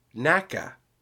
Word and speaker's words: NACA: Interestingly enough, during its existence, the organization was pronounced as an initialism, meaning as each individual letter, NACA. However, when it was replaced by NASA in 1958, people then assumed that it had been pronounced NACA, and now both pronunciations are considered correct.